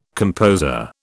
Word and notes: composer: British English

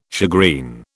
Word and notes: shagreen: British English

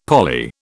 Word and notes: poly: British English

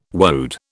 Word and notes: woad: British English

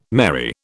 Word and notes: merry: British English

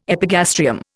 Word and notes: epigastrium: Indian

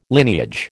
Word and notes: lineage: American English

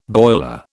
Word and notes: boiler: British English

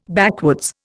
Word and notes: backwoods: Indian

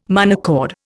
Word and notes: monochord: Indian

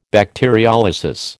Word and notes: bacteriolysis: American English

